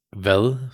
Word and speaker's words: Hvad: Again, it's vel, so the H doesn't get pronounced. H-V-A-D, vel med dig.